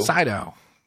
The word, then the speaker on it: Sydow: And Max von Sydow? Is that how you pronounce it? – Sydow. – Yes.